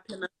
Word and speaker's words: Plymouth: Did I say that right? Is it Plymouth? – Yeah, Plymouth.